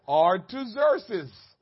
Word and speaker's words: Artaxerxes: The king Artaxerxes, King Artaxerxes, who ruled, I'll say it again, Artaxerxes. You want me to spell it for you? If you want to get technical, A-R-T-A-X-E-R-X-E-S, King Artaxerxes. The school teacher just told me, come on.